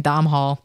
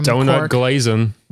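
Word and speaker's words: Domhnall Gleeson: But you know who really should be in Best Supporting Actor is Donald Gleason, who our wonderful fan told me how to pronounce because I kept saying Domhall. – Mm-hmm. – Thank you, Twitter fan from Donut Glazin.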